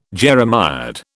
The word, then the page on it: jeremiad: British English